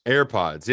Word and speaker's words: AirPods: Uh, and then I bought uh, how you say it? earpods? AirPods, yeah. AirPods.